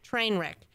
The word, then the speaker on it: Treynwrek: It's spelled T-R-E-Y-N-W-R-E-K, Trainwreck.